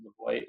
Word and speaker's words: Levoit: It's Levoit. I think you pronounce it Levoit. It's L-E-V-O-I-T.